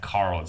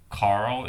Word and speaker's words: Carl: You can follow him on Twitter at DanCohen3000 and also follow me on Twitter at Carl Zha. Carl is spelled with a C.